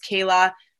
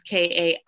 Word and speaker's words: Kaila: But my name is Kayla, not the usual way. It's spelled with an I. It's Kaila, not with a Y.